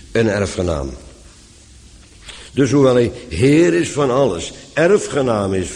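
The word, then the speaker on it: heir: In de praktijk is dat woord heer een tamelijk subtiel woord, want spreek je hetzelfde woord in het Engels uit, betekent het ineens erfgenaam. Engelse heer, H-E-I-R, R spreekt men dan uit, voor alle duidelijkheid.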